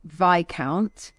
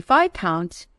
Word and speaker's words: Viscount: And he did kind of look at me a little bit horrified, saying, "It's Viscount." I'd never even heard the word Viscount before. And then, of course, I proceeded to say, "I don't understand why it would be Viscount. It's spelled exactly like discount."